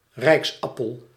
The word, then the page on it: держава: [dʲɪrˈʐavə]; deržava